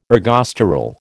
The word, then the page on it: ergosterol: American English